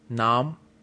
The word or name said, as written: nam